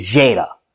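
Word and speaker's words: Jayida: You know, anytime somebody pronounced my name, they can't pronounce it. They literally be like, Jada. I'm like, it's Jada. It's not, I don't know where y'all are pronouncing, I don't know how y'all pronounce my name wrong, but, and I'd be like, my cousin, so I have another cousin.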